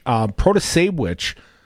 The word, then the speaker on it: Protasiewicz: I don't think that they see the Protasiewicz, which pronounced like witch, Protosewitch, is, if anything, is probably the party choice.